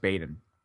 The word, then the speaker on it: Baden: French Lick and West Baden. Baden. I always say that wrong. West Baden.